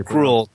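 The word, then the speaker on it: Krul: Well, in that, they had we found out that J.T. Kroll is not J.T. Kroll. He's actually J.T. Kroll is how you're Kroll. That's correct. – Yes, the correct pronunciation of his name is Kroll.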